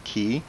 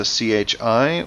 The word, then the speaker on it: qi: I've actually seen the Japanese pronounce it ki, and I've seen it spelled with a chi or with a qi. How do you pronounce it? – I pronounce it chi. – Mm-hmm. – Which is, very close to what you said, so it's correct.